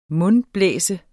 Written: mundblæse

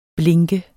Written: blink

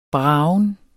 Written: bragen

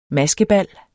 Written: MASKEBALS

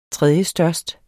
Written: tredjestørst

